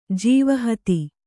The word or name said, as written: ಜೀವಹತಿ